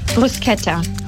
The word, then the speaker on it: Bruschetta: Bruschetta. – Ah, sehr gut, sogar richtig ausgesprochen. Hätte ja sein können, du sagst Bruschetta.